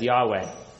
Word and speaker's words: Yahweh: Those caps simply point out that this is the great I am who I am, the tetragrammaton YHWH, which we usually pronounce as Yahweh.